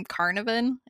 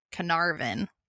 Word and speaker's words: Carnarvon: I kept calling him Carnarvon. It's not. It's Carnarvon.